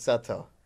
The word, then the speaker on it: subtle: It's subtle. – Subtle. – Subtle. Yeah, subtle. The B is silent.